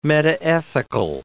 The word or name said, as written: metaethical